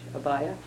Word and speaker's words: Abiah: son of Joseph and, I don't know if I can pronounce this, A-B-I-A-H, Abaya? – Yeah.